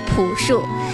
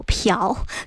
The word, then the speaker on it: 朴: 很多人喜欢他的歌，也喜欢他的音乐，更多的人喜欢叫他朴树。其实从正确的读音来说，这个字用作姓的话，应该叫朴，朴树的。